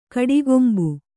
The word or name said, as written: ಕಟ್ಟುಕಂಬ